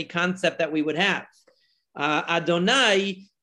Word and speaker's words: Adonai: We always pronounce it as Adonai.